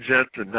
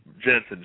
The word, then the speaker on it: Jensen: Brian Jensen, not Jenkins. Don't listen to anything that Tom says about my last name. It's Sparky Super Colts fan. Jensen, J-E-N-S-E-N.